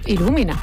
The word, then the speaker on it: Ilumina: Ilumina. – ¿Sí? Ilumina. – Sí, con acento la U.